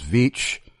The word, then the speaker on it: Veach: I mispronounced it earlier as Veitch, and he emailed me informing me that it is Veach, but told me that people have been butchering his name for decades, so I had nothing to worry about.